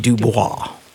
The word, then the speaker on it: Dubois: Special guest today, Elizabeth Dubois. It should be Dubois. The proper French pronunciation.